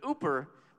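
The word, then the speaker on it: uper (ὑπέρ): That Greek word has this prefix on it called "uper." Now, I don't speak Greek and you don't either. But "uper" rhymes with super.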